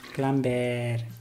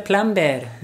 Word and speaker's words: plumber: Y toda la vida he dicho plumber. – Plumber. Plumber. Necesito un plumber. I need a plumber. What? So you need a plumber. Plumber. So the B is silent. It's just plumber. Let's hear you. – Plumber. – Plumber. – Plumber. – Perfect. – Ah, entonces no pronuncias la R tampoco. – No, it's just the schwa sound. Plumber, teacher, butcher, writer, computer. It's all with the schwa sound at the end.